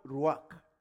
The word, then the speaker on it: ruach: Now, the word, we are told that the Hebrew word that is called, that was translated spirit there, is the Hebrew word ruach. R-U-A-C-H. My pronunciation may be the Nigerian version.